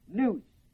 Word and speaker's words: nous: The first word is the word psuche, spelled P-S-U-C-H-E, with a long E. Psuche, P-S-U-C-H, long E. The next word is the word nous, N-O-U-S. The third word is the word pronema, spelled P-H-R-O-N, long E-M-A, pronema, P-H-R-O-N, long E-M-A.